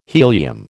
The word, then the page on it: helium: American English